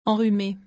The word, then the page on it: enrhume: French